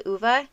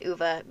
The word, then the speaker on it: Ove: So it's a man called Uwe, and his name is actually spelled O-V-E, and I only know it's pronounced Uwe because...